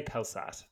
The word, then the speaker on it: Pelsaert: P-E-L-S-A-E-R-T. – Sart? I would say Pelsart. Maybe Sart, but that sounds a bit French. – We'll say Pelsart. That's fine. And with Arian Jacobs serving as skipper. Now, Pelsart